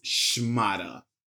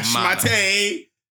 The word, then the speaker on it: shmata: This week's word of the week is shmata. All right, that's how you pronounce it. Okay, shmata. – Not shmate, shmata.